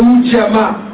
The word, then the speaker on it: Ujima: It's pronounced Ujima.